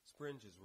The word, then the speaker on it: springes: And it actually says in the notes, springes rhymes with hinges.